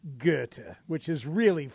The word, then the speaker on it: Goethe: Hey, by the way, anybody know how to pronounce the Chicago street that is spelled Goethe? Goethe. – Goethe. – No, I believe it's Goethe. Based on the German philosopher. I believe the correct pronunciation is Goethe.